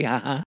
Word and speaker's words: jaja: H-A-H-A, ha-ha. They'll spell it J-A-J-A. And of course, we'll pronounce it ja-ja, ja-ja. But they'll pronounce it ha-ha, ha-ha, because J sounds like our English H in Spanish. So if you've ever wondered when you saw the letters J-A-J-A, what the hell's going on, it just means ha-ha.